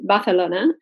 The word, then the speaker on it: Barcelona: And it's funny, like we say in English Barcelona, but obviously correct is Barcelona.